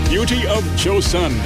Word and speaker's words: Beauty of Joseon: Beauty of Joseon.